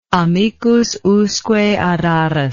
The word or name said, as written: amicus usque ad aras